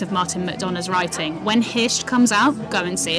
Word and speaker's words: Hirta: He's working on a play at the moment called Hisht, which is spelt H-I-R-T-A, Hisht, which is an island...